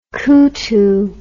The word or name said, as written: kutu